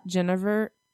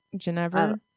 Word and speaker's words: genever: I don't like the Dutch style gin as much as I like the, I call it Genevieve. Like Guinevere, except Genevieve. – That's probably smart. – What, how did you say it? – Genever. Genever. It's like G-I-N-E-V-E-R.